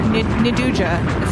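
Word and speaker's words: nduja: Induja is how I like to pronounce it.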